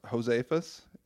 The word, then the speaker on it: Josefus: I want to say it's pronounced Josefus. It's J-O-S-E-F-U-S.